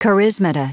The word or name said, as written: charismata